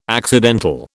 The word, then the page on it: accidental: British English